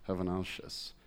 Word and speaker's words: Hazanavicius: H-A-Z-A-N-A. – Hold on. H-A-Z-A. – N-A. – N-A. – V-I-C-I-U-S. Hazanavicius. – I think you say that. Z-Z-Vaza. – Hazanavius. – Nice one. – Hazanavicius. Hazanavicius.